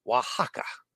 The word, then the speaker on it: Oaxaca: I didn't know that the OAX in Spanish is pronounced "wa." Oaxaca uh, and I should have known that because I know the the word, I know the name from my travels in Mexico.